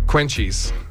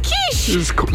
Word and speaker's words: quiche: Like, have you seen how quiche is spelled? – Yes. – It's quenchies. – It's quiche.